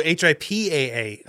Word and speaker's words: HIPAA: We all totally butchered that acronym, by the way. – Oh, really? – It's HIPAA. – Oh, HIPAA.